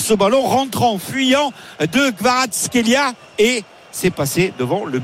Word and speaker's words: Kvaratskhelia: Et on va avoir un coup franc effectivement dangereux avec justement Kvaratskhelia. Vaut mieux le dire doucement que vite.